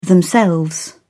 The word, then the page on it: themselves: British English